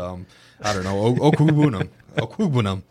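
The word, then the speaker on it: Okwuegbunam: OKWEGBUNAM. – Okwugbunam. – Perfect. You did it great.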